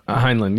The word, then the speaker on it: Heinlein: Heinlein, yeah.